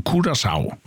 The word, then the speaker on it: Curaçao: Och, ja, och vi kommer att säga att landanavnet Curaçao uttalas Cura, K-U-R-A-S-A-U av vårskan Curaçao.